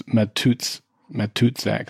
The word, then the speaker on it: Matuszak: Well, and the reason I knew how to pronounce John's name was because when he played for the Raiders or the NFL, they called him the Toots. So it's Matuszak, and that's where they got the Toots from.